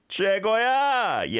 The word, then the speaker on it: 최고야: 최고야. Yes, and it is spelt with an o e we vowel sound, 최고, but we don't overly pronounce 최, so that's okay the way you've done it as well.